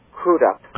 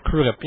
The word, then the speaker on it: Crudup: Billy Crudup was wonderful, or I believe he's called Crudup. – Crudup, yeah. – Crudup, yes, because he thanked the presenter for pronouncing his name properly.